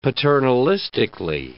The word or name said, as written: paternalistically